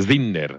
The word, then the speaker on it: Sinner: Poi si pronuncia Sinner, sentito ieri sera a tavola.